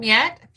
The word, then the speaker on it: Jet: So if you hear from Yet, it's spelled Jet, but Yet, she's Dutch.